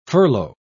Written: furlough

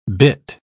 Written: bitt

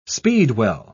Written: speedwell

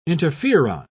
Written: interferon

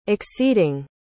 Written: acceding

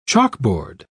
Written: chalkboard

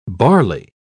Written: barley

